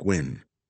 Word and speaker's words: when: I haven't heard from you, and I can't think of when, she added, pronouncing the word when like win. Pin-pen merger, Charmaine thought. The linguist's term for when someone takes words ending with E-N or E-M and turns them into I-N or I-M, most often found in southern states.